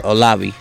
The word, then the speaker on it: Olave: I don't know if I'm saying it right. Chris Olave. I believe Olave.